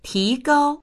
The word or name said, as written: ti2gao1